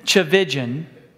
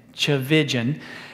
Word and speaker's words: Tchividjian: I was reading a book by Tullian Chavijan. That's his name. I won't spell it for you. Well, I will. Okay, his last name is T-C-H-I-V-I-D-J-I-A-N. Chavijan.